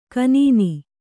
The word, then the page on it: ಖಿನ್ನ: Kannada